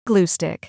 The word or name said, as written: glue stick